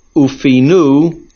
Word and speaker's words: ufinu: The word ufinu means they should empty, they should clear. Make sure that you pronounce the word ufinu, milra, on the last syllable.